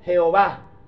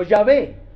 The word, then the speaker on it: Yahvé: Pero tal parece que la pronunciación más correcta no es Jehová, sino Yahvé. Así que en adelante, nada de Jehová.